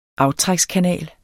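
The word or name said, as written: AFTRÆKSKANAL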